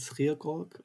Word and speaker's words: Schierkolk: Okay, ich bin Jan Schierkolk, oder wie mein Nachname im Original ausgesprochen wird, Schierkolk aus dem schönen Holländischen, und bin 37 Jahre alt, bin tätig bzw.